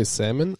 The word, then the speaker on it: salmon: All right, do you say salmon or salmon? – Salmon. – Salmon. – Okay. – If anybody pronounces the L in salmon, they deserve an uppercut.